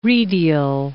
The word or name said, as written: redial